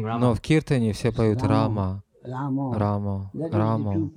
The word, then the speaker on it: Рама: Махарадж, один из моих духовных братьев сказал мне, что мы не должны говорить «Рама», слово «Рама», повторяя «Харе Кришна Махамантру». Он сказал, мы повторяем «Харе Рама», но он сказал, это не шудха, не чистое повторение.